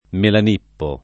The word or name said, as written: Melanippo